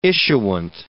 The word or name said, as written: issuant